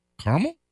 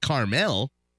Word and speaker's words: caramel: Yeah, it's not Carmel. We're not saying it Carmel, bro. We're saying the A. – Well, let's see. If only there was a way to differentiate how many syllables there are and phonetically how it's supposed to be pronounced. There is a way right here. Look, there's a syllable right in the middle there. The A gets its own beat.